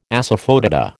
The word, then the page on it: asafoetida: American English